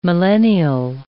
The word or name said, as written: millennial